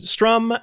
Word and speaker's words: Strom: Uh, you can find me on Twitter at, at Steven Strom, S T E V E N S T R O M.